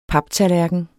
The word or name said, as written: paptallerken